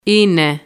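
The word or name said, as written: είναι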